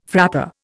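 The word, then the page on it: frappé: Indian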